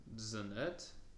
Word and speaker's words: genet: Genet, G-E-N-E-T. Let me see how to pronounce it first. Zanet? Genet. We're calling it Genet. G-E-N-E-T.